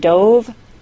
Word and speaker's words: dove: And dove rhymes with stove. Here's one last example, this time with the A-I-D spelling. Said, S-A-I-D, doesn't rhyme with paid, P-A-I-D.